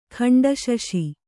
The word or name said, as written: ಖಂಡಶಶಿ